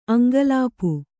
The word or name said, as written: ಅಂಕಲಿಪಿ